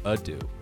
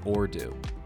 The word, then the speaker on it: ado: So without further ado, actually, Robin says that I've been saying that wrong. It's further ado, not or do.